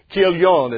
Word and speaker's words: Chilion: They were there for 10 years, or Naomi was there for 10 years, and after 10 years, both of her sons, Mahlon and Chilion, is how you actually pronounce those names in the Hebrew, they passed away in the land of Moab.